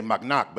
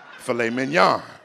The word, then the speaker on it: filet mignon: Back in the hood, I used to say mac knock, but now I know how to say the word filet mignon.